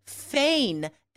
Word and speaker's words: feign: It's spelled like rain, right? F-E-I-G-N. It really does not look like it's pronounced, so that's tricky.